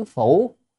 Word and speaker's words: phủ: Đắc sanh giữ phủ, tức là được sanh hay không đó, chữ đây này đọc là phủ.